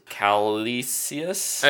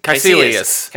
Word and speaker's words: Kaecilius: I just because I don't know his I can't pronounce his Calisius. Uh, it's Kaecilius.